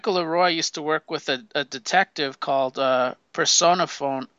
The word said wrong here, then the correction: Persephone: I don't even know how to pronounce that name. PERSEPHONE. Persephone? – it's Persephone, That's a weird name.